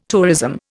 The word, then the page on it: tourism: Indian